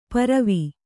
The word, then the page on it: ಪರವಿ: paravi